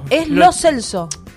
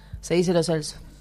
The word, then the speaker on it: Lo Celso: Cada uno tenía que decir cómo se pronunciaba su apellido. Y ahí Lo Celso dijo, che, se dice Lo Celso.